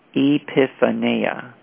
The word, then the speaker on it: epiphaneia: The word translated, quote, brightness, quote, is, quote, epiphaneia, quote, and that's pronounced E-E space P-I-F-F space A space N-A-Y space A, epiphaneia, from which we get the word, quote, epiphany, quote.